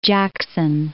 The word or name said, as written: Port Jackson